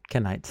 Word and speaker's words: Kenites: So it's a group of people called the Kenites, spelled K-E-N, like Ken, Kenites.